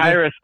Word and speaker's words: Khyiris: Khyiris Tonga. Khyiris.